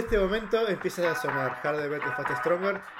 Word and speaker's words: Harder, Better, Faster, Stronger: Harder, Verder. – Faster, Stronger. – Faster and Stronger. Por favor, y mi pronunciación en inglés la vamos a mejorar la próxima. Harder, better, faster, stronger.